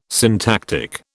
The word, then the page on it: syntactic: British English